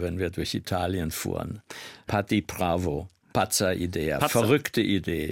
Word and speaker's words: Patti Pravo: Patti Pravo, richtig ausgesprochen? – Ja.